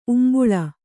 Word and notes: ಉಂಬುಳಿ: Kannada

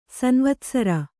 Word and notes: ಸಂವತ್ಸರ: samvatsara

